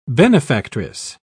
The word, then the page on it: benefactress: British English